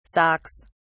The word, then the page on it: socks: British English